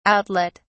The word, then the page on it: outlet: British English